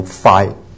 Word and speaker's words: phi: 那这个末子音呢，你注意在下面 number three 啊，可以分三类啊，这个纯音的像 pi beta 或者 phi 啊，那都是用嘴唇的爆破发音的，啪啊，悲啊啊，拍啪啪，悲飞啊，我读 fi 啊，都是属于嘴唇的一种的爆破音。